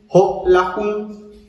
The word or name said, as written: jo'olajun